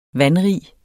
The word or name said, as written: vandrig